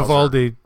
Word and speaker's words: Vivaldi: Vivaldi browser, yeah.